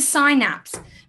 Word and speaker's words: synapse: And I just realized that I actually just said that wrong. It's a synapse.